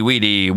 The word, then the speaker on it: vidi: Or Weenie Weedy Woda, I think the correct Latin pronunciation would be.